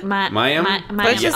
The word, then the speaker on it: Mayim: So, how do you pronounce it? – Mayim? – Mayim.